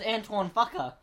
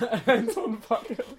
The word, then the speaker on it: Antoine Fuqua: I adore The director's name is Antoine Fuqua. – It is. That's exactly how you say it. – Antoine Fuqua.